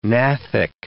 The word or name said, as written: gnathic